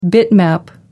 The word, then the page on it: bitmap: American English